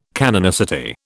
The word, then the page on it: canonicity: British English